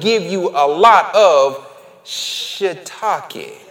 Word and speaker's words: shiitake: Loved ones, if you look at that phonetically, that S-H-I-I is shuh. Shiitake is how you say it.